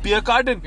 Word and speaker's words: Pierre Cardin: And their uniforms are sewn by Pierre Cardin. – That's how they say it.